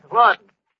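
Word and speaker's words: Laughton: Lawton, Duffy. L-A-F-F-T-O-N. Yeah, Lawton. That's how the English spell it. Yeah, you know, like the London Times is pronounced the Thames.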